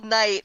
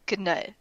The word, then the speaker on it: knight: I just googled knight, and so they have the origin breakdown. In old English, it's spelled C-N-I-H-T. So yes, it's knight. – Right. Yeah. – I don't know where the G came from, but it's there.